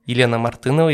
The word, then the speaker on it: Елена Мартынова: Это Елена Мартынова, если правильно все я произношу.